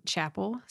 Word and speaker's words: Chappell: So it's M-E-G-G-A-N, last name Chappell, C-H-A-P-P-E-L-L. Lots of doubles. – Chappell. – Yep. – Okay. – Like a church chapel.